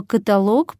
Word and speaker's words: каталог: Если речь идет о собеседовании, на которое вас пригласили после одобрения вашего резюме, то слово «каталог» произносите только с ударением на последнем слоге.